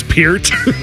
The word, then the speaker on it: Peart: It's Peart.